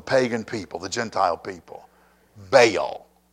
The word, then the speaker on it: Baal: That he married Jezebel, the daughter of Ethbaal, king of the Sidonians, and went to serve, I'm going to pronounce this, by the way, Baal. Okay? B-A-A-L. Some pronounce it Baal. Some pronounce it Baal. I'm from the south. I'm calling him Baal. All right? B-A-A-L.